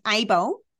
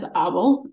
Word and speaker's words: Abel: Abel. – I know. Yeah. I'm not fussy about it, but the truth is I founded the business in Amsterdam. And so the Dutch pronunciation is Abel.